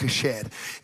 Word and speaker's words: chesed: It's spelled like C-H-E-S-E-D. You almost say like chest, but it's actually pronounced kesed.